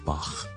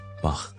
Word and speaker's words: Bach: 用一喉咙嘅一个轻微嘅摩擦音，voiceless 嘅声音，我哋叫 Bach Bach。